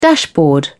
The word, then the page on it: dashboard: British English